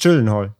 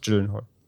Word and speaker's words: Gyllenhaal: Es, es, war ja am Ende sogar tatsächlich diese schwedische Aussprache, Gyllenhaal, ne? Ich glaube, das war das Richtige, ne? – Nee, es ist Gyllenhaal. – Er sagt selber, okay. es ist Gyllenhaal. – Also, wenn er vielleicht in der Heimat geblieben wäre oder in seiner Heimat wäre, dann würde da vielleicht jemand anders aussprechen, aber er sagt immer so Gyllenhaal.